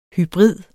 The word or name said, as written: hybrid